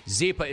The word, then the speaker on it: Zyppah: Zypa is spelled Z-Y-P-P-A-H. It's Happy Z spelled backwards.